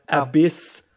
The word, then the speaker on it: Abyss: Nee, also, er hat es einfach nur trashig ausgesprochen, das heißt Abyss.